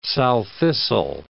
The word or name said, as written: sow thistle